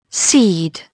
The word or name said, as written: seed